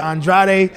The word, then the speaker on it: Andrade: No, I'm Cape Verdean, and of course my culture, my ethnicity, my background, they pronounce it Andrade.